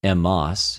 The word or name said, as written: ἐμος